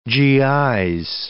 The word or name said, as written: GI's